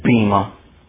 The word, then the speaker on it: Bema: If you ever hear anybody pronounce that Bema, look down on them. They don't know how to pronounce that word. It's not Bema. It's transliterated that way in English, but it's an Eta, not an Epsilon.